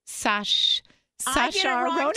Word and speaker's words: Saoirse Ronan: So all of the looks, even, and I always say her name wrong, I always want to say Saoirse Ronan, but I think it's like Saoirse Ronan.